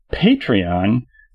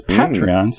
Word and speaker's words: Patreon: Especially since Patreon sounds like the good fellas screw you, pay me. Whereas Patreon sounds like you're just giving it a nice pat on the back.